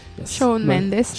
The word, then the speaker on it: Shawn Mendes: Allora, Shadow Mendes. – Shawn Mendes, mamma mia la pronuncia.